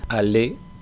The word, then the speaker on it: allez: Habréis observado que, en primer lugar, allez no suena la Z final, es decir, la terminación EZ se pronuncia como una E normal, allez.